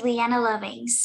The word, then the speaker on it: Leana Lovings: And am I saying it right? Leana or Leana? – Yes, it is Leana Lovings.